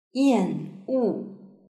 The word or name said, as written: yanwu